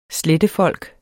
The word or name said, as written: slettefolk